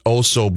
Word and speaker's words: OHSO: And I got a great recommendation from it's I believe it's pronounced Oso Brewing, O H S O.